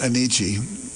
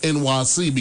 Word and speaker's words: Enyce: It's crazy because it's spelled like E-N-Y-C-E. – Yep. – Oh, okay. Yep. I never knew how to say it. – Enichi. – Is Enichi, but then I heard like many people say like it's pronounced NYC because E N Y and then you got C E.